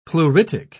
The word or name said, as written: pleuritic